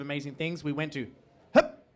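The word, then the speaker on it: Höfn: Because, you know, if anyone doesn't know, it's spelled H-O-F-N. And it's pronounced... Hop!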